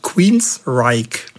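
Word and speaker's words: Queensrÿche: Also Queensryche. Und übrigens, seinen Vornamen schreibt man ja G-E-O-F-F, aber man spricht nicht Joff, wie viele Leute das tun, sondern Jeff.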